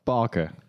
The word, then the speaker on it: Baecke: That's close enough. Bakke.